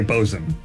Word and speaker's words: boatswain: Okay, but that's like but boatswain. They say bosun, right? – Give him a half point.